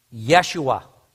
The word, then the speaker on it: Yeshua: Had Jesus been born while people were speaking Hebrew, Jesus' name would have been pronounced as what? Yeshua.